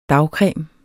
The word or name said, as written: dagcreme